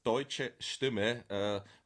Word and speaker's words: Deutsche Stimme: It's Deutsche Stimme, Voice of Germany translated.